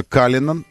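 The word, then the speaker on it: Cullinan: И они стоили, это Каллинан. И, кстати говоря, именно так произносится.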